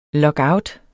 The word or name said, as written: lockout